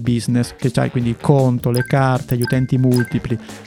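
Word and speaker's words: Qonto: Stiamo parlando di Conto. Con la Q con la Q ma si pronuncia tipo conto.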